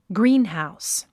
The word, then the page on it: greenhouse: gríːnhàus; グリーンハウス